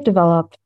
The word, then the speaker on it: Developh: I say Develop. Yeah. – Develop. Okay. But there's an H at the end.